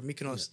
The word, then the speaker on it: Mykonos: I said Mykonos or whatever. Mykonos. Yeah. Yeah. He said, that's the spot to go. And we're like, all right. Maybe that's the right way. I'm probably pronouncing it wrong. – You know, it is. – No, I think the way you said it. – Yeah, you probably said it correctly.